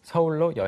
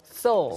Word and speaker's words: Seoul: Seoul, S-O-U-L, 영어로 이제 영혼이지만 또 서울이라는 지명이 영어로는 또 Seoul처럼 발음이 됩니다.